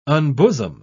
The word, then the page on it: unbosom: British English